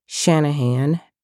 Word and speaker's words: Shanahan: First of all, my name is Kanika, and it's spelled K-N-E-I-K-H-A Shanahan, S-H-A-N-A-H-A-N.